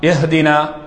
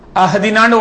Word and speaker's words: اهدنا: ஹா அது மாதிரி ஹா எஹ்தினா, அங்க ஹா சொல்லக்கூடாது, அஹ்தினான்னு ஓதக்கூடாது. எஹ்தினாவுக்கு பாரமாக அஹ்தினான்னு ஓதினார்டா கருத்து மாற்றம் அடைந்து விடும்.